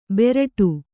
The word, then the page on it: ಬೆರಟು: beraṭu